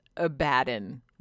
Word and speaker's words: Abaddon: The way that they pronounce it, like the actual Games Workshop, is Abaddon, like a a a bad one.